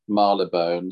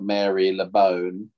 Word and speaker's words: Marylebone: They arrived at a station that is always interesting for people to pronounce. Marylebone. It looks like spelt Mary LeBone. – Marylebone, yes.